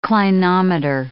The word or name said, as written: clinometer